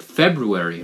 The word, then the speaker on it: February: In that case, you could just forget the R, right? It would just be February, but it's got that, you know, R in the middle, so you gotta pronounce it February, right?